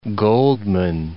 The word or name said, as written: Goldman